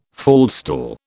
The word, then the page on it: faldstool: British English